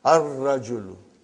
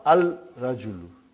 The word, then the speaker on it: ar-rajulu: Ar-rajulu. The man. Not al-rajulu. It will be wrong to say al-rajulu. It will be ar-rajulu. We won't pronounce love. Why? Because it is a son letter.